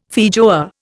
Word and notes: feijoa: Indian

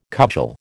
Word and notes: cudgel: American English

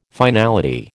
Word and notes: finality: American English

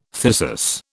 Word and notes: thyrsus: British English

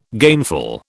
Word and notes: gainful: British English

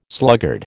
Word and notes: sluggard: American English

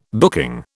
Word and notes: booking: British English

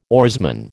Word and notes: oarsman: American English

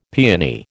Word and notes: peony: American English

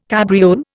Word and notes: cabriole: Indian

